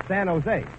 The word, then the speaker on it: San Jose: It's San Jose. In California, the J is pronounced like an H.